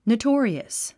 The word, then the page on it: notorious: noutɔ́ːriəs; ノウトーリアス